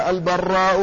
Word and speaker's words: البراء: فهناك من الرواة من اسمه البراء بتشديد الراء، ومنهم من اسمه البراء بتخفيف الراء. قال ومن ذلك البراء والبراء، فالأول بتشديد الراء وهو اسم لمين؟ من كنيته أبو معشر. قال ذو كنية بمعشر يقال فيه البراء، واسمه يوسف بن يزيد، وحديثه في الصحيحين يعني في البخاري ومسلم.